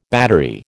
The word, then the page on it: battery: American English